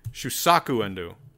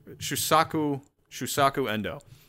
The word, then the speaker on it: Shusaku Endo: I gotta get his name right. Shusake Endo. Shusaku Endo. Shusaku Endo.